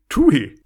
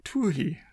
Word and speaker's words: Twohy: T-W-O-H-Y. Tuhi. Tuhi. – Tuhi.